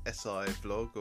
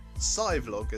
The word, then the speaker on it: SciVlog: My voice may be familiar for those of you who have followed my YouTube channel, SciVlog. Yes, that's how it's pronounced. Less of the SIVlog or CVlog, as I've heard some people call it. But anyway, this isn't SciVlog as such.